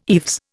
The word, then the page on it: eaves: Indian